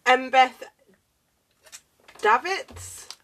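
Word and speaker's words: Embeth Davidtz: Embeth Davids.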